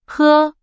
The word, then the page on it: 喝: Chinese